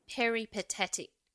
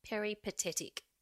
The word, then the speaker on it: peripatetic: Peripatetic. Oh, I don't think I said that right. Peripatetic. There you go. – That's it.